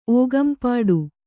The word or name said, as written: ಓಗಂಪಾಡು